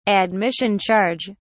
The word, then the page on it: admission charge: British English